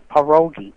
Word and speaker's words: pierogi: If I say pierogi, people don't know what I'm talking about. I have to say pierogi. That's the name it seemed to have retained over the years, but the correct pronunciation is pierogi.